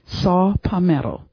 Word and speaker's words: saw palmetto: Saw palmetto. Is that how you pronounce it? – Saw palmetto. – All right. – S-A-W-P-A-L-M-E-T-T-O.